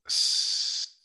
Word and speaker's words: scent: So is the S silent? I think the C is silent. – I would agree. – Scent.